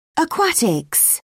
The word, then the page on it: aquatics: British English